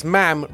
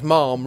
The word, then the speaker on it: ma'am: Because Ma'am rhymes with Lamb, not Ma'am rhymes with Palm.